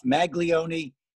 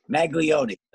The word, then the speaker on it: Maglione: Close enough. Maglione is how most people pronounce it. I've had a few pronounce it different things like Magnolia or Macaroni, but Maglione, so you're close.